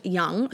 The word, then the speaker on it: yang: yang. Actually, it's pronounced young, so yin and yang.